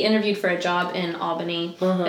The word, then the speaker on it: Albany: And so anyway, he came back home to Tallahassee from this interview, and he was like, everybody up there pronounces it Albany. And I was like, I could have told you that. like, like, that is exactly how you're supposed to pronounce yeah, it.